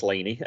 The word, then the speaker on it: Laine: I think it's pronounced Laine, L-A-I-N-E.